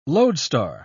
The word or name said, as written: lodestar